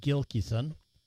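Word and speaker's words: Gilkyson: Sort of alternative indie folk singer-songwriter Eliza Gilkison, up Gilkison, there's no N in it, I mispronounced it, Gilkison, from her album Seculera.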